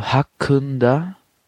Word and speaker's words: hakkında: Beim Hakkında müsst ihr aufpassen. Und zwar, das I ist ohne Punkt geschrieben im Türkischen. Und das bedeutet, dass es, wie soll ich sagen, sehr stumpf so ausgesprochen wird. Also mehr so ein Ö statt ein I. Und also Hakkında. So wird es ausgesprochen.